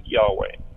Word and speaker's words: Yahweh: For Nephi, the God of Israel is the premortal Christ, sometimes called Jehovah, or in Hebrew transliterated into English as YHWH, or pronounced Yahweh.